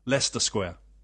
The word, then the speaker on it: Leicester: No, okay, well, I would pronounce that Leicester Square. – Leicester Square. – Leicester Square. Leicester. Leicester. Because you wouldn't put it shish, would you? Like a shh.